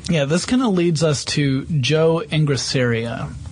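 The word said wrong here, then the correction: Engressia: Ingresia. I'm sorry, I mispronounced that last name. Ingresia.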